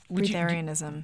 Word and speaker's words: breatharianism: But let's talk a little bit about this I'm not even sure how you pronounce it. Breatharianism, is that? – Oh yeah. Breatharianism. – Breatharianism, yeah.